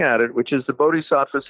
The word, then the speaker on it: Bodhisattva: but integrating it with a sort of Bodhisattva path, you know, a Buddhist sort of, I'm probably not pronouncing that word right, but Bodhisattva path.